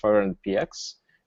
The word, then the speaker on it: 500px: And we wanted to make sure that it's clear how it's supposed to be pronounced and how it's supposed to be said. So now it's clear that it's 500px.